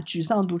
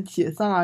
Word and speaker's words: 沮丧: 你演出来呈现的时候是需要一些文化水平才能演得出来的，不然可能会出现曾经的艺人把沮丧读成解丧啊这种水平的东西。